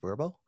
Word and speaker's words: VRBO: VRBO is actually pronounced Verbo.